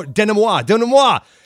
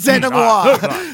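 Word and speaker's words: dénouement: Last week, if you recall, I totally boned the denouement pronunciation. I think I say, oh, denouement. – I was going to say, dude, I hate to tell you that you did it because it's denouement.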